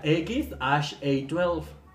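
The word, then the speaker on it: X Æ A-12: Musk dijo que el nombre de su hijo se pronuncia X A12.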